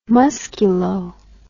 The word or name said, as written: musculo